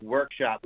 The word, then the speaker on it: WRKSP: What is WRKSP? – Yeah, it's actually pronounced workshop. We took the vowels out.